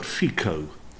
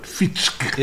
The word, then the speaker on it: Fico: I pronounce his name as if he's Italian, Robert Fico. – Yes. – I think it's probably something like Robert Fitschke.